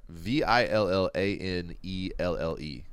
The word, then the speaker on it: Villanelle: All right, V I L L A N E L L E. What do you think, Villanelle? – I mean, it sounds like you pronounced it wonderfully.